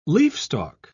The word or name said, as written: leafstalk